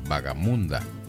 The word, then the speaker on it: vagamunda: Escuchamos la voz de el cardenal sabanero don Reinaldo Armas en ese tema que algunos nos han dicho que está ahí una palabra mal pronunciada, vagamunda, que no es la palabra correcta, sino vagabunda. Pero yo conversé con alguien preguntándole también por ese, me parecía rara. Ahorita estábamos conversando aquí el operador y yo, y la palabra está bien, bien pronunciada en este caso,